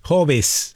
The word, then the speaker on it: Hobbes: Se escribe H-O-B-E-E-S, Hobbes.